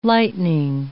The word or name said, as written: lightning